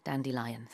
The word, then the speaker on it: dandelion: Just like a lion, 사자 같은 그런 발음이에요. Dandelion, 사자처럼 생겼잖아요.